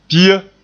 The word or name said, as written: pia